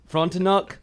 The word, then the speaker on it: Frontenac: The famous hotel there, the Chateau Frontenac, that's not how you say it. Frontenac.